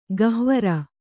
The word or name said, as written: ಗಹ್ವರ